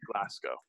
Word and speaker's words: Glasgow: So shout out to all of our Scottish listeners who have been pounding the table saying we're saying it wrong. Now we can officially know it is absolutely Glasgow.